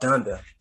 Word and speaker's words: Donda: Donda.